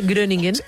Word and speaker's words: Groningen: Doncs Frank Lluís Giró, ja ho sabeu, és l'editor del Nota de Veu, avui en aquesta connexió des de Groningen, que ho pronuncien així, eh? Groningen.